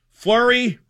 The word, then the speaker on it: Fleury: But it turns out chanting his name, Joe, Fleury rhymes with Murray.